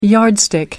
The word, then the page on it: yardstick: American English